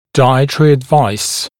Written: dietary advice

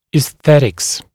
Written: aesthetics, esthetics

